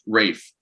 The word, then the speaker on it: Ralph: It's pronounced Ralph. It's Ralph Fiennes.